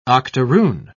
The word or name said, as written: octoroon